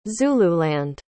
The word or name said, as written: zululand